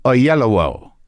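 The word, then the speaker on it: Oyelowo: I just watched him pronounce it on a bit of video to make sure I knew how to pronounce it. Ayelowo.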